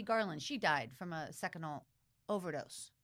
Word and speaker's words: Seconal: Like a Seconal. Seconal. Is that how you say it? Seconal? – Yes.